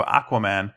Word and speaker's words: Aquaman: And, you have the weirdest pronunciation of Aquaman I've ever heard. – Aquaman? – Yeah. – I don't know. I've never met him in person. – You really pushed that first A.